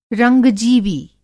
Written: रंग-जीवी

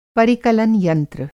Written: परिकलन यंत्र

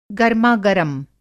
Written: गरमागरम

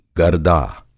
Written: गरदा

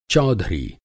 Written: चौधरी

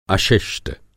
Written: अशिष्ट

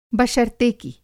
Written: बशर्तेकि

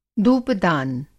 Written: धूपदान

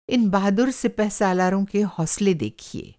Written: सिपहसालार

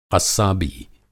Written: क़स्साबी

